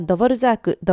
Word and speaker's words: Dvořák: 日本ではドボルザーク、ドボルジャークとどちらかの発音で呼ばれていると思います。